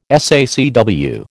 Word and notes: SACW: American English

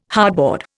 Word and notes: hardboard: Indian

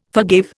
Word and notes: forgave: Indian

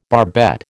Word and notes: barbette: American English

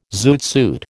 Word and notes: zoot suit: American English

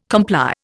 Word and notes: comply: Indian

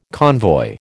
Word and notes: convoy: American English

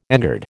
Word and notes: engird: American English

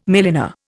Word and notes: milliner: Indian

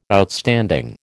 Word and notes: Outstanding: American English

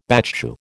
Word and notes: battue: American English

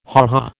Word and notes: ha ha: American English

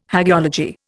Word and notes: hagiology: Indian

